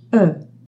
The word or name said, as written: oe ligature